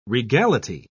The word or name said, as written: regality